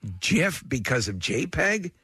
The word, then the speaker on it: GIF: But he, all I know is he said that he pronounces it with a soft G. He described it, he declared that pronounced with a soft G, like peanut butter brand GIF.